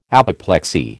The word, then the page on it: apoplexy: American English